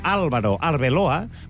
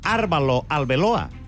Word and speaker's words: Álvaro Arbeloa: Tu vols dir Álvaro Arbeloa, però fàcilment pots acabar dient Árbalo Arbeloa o Álvalo Arbelola.